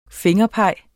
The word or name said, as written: fingerpeg